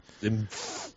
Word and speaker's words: Senf: Senf aber bitte ohne N, sondern das N wird MP ausgesprochen. – Senf. – Genauso wie bei Hanf, bei Fünf und bei Genf.